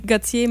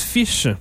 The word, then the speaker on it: ghoti: Eh ben, donc, G-H-O-T-I, on prononce tout ça Gauthier, mais en fait, ça se prononce Fish.